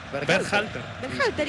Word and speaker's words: Berhalter: Berhalter.